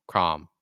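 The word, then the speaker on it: Chrom: He's he's basically a prince of his country and there's like Crom. Is it Crom or Chrome? Crom. It's Crom.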